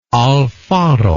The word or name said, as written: alfaro